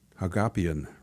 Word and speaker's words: Hagopian: She had an author of Teaching Truth on Hagopian rhymes with utopian.